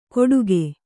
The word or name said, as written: ಕೊಡುಗೆ